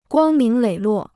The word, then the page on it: 光明磊落: guāng míng lěi luò